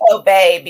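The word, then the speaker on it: Hobé: It's pronounced Hobie because there's a little thing over the E.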